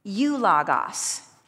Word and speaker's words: eulogos: This word is eulogos. E-U. Now follow me in as I spell it for you. E-U. L-O-G, does it sound familiar? E-U-L-O-G, and then O-S is the Greek word, eulogos.